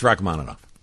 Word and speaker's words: Rachmaninoff: Because today I had Rachmaninoff. – Yeah, it's Rachmaninoff.